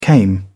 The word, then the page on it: came: British English